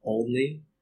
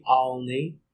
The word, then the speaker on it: Olney: I know it's supposed to be Olney, or if you want to get even more Philly, Olney. So for one, I wanted to acknowledge the half-joking slash contentious debate on the pronunciation.